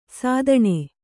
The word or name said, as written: ಸಾದಣ